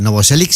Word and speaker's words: Novoselic: Pero Novoselic, ¿no? Lo he dicho bien, ¿no? – Es como motor. – O sea, Novoselic sí.